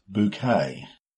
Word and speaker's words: Bucket: It's the name of a British comedy TV show, and it's all about a woman called Hyacinth Bucket, which she prefers to pronounce bouquet.